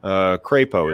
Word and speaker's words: Crapo: Crapo is how it's actually pronounced.